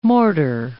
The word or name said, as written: mortar